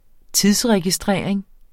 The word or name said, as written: tidsregistrering